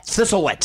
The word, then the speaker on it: Thistlewit: Thistlewit.